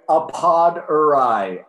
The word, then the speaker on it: Appadurai: Appadurai.